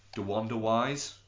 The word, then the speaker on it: DeWanda Wise: DeWanda Wise. Did I pronounce that anywhere near right? – Yeah.